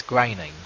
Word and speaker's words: Groening: That's how you say it. It's groaning. You don't say like your mama groaning. – It looks like the word groaning. Except for the E.